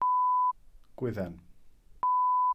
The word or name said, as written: gwydden